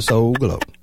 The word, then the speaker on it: Soul Glow: But you got to say it the right way. – How do you say it? – Soul. That's right. Brother Soul Glow.